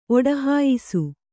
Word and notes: ಒಡಹಾಯಿಸು: Kannada

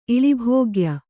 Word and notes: ಇಳಿಭೋಗ್ಯ: iḷibhōgya

